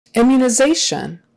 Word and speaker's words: immunization: Immunization. Immunization. Typically, when a word ends in that T-I-O-N, shun, the second to the last syllable is going to be stressed.